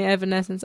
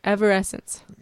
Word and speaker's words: Evanescence: Evanescence. – Mom knows it. It's a hard name. It's a hard name. – It is not because when Logan first showed me Evanescence, I was like Everessence, right? – Evanescence.